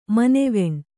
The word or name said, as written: ಮನೆವೆಣ್ಣು